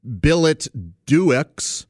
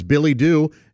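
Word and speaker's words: billet-doux: Now, it's spelled billet, B-I-L-L-E-T, hyphen, or dash, D-O-U-X. So it looks like billet-doo-X, but it's not. It's Billy-Doo, and it means a love letter.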